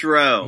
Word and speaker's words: Rowe: And his last name rhymes with no. – James Rowe.